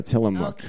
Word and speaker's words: Tillamook: Yeah, Tillamook. – Okay, fine. – Tillamook.